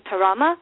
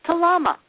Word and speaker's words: Tarama: So he thought all these years when he when she said I'm from Tarama that she was not able to say the L, so he thought she was from Talama. She was actually saying it correctly. She's from Tarama.